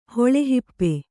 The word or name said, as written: ಹೊಳೆಹಿಪ್ಪೆ